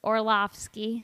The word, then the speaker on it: Orlovsky: It says one, Dan Orlovsky. I was close, right? – Orlovsky. – And then he puts it pronounced just how it looks.